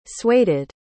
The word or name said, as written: sueded